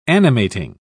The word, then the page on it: animating: British English